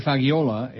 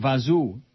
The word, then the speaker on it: fagioli: It says, depending on the dialect of Italian being used, the word fagioli may be pronounced as it's spelled or close to fagiol, even vazu, pasta vazu. A 1927 song by Van and Schenk capitalizes on the latter pronunciation in the rhyme, don't be a fool, eat pasta fagiol.